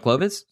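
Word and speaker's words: Clowes: You say Clovez, it's C-L-O-W-E-S. Why are you saying it with a V? – Because I've interviewed him, and he pronounced his own name, Daniel Clovez. So it's Clovez with a V? That's how you say it?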